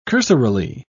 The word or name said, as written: cursorily